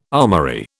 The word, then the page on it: armoury: British English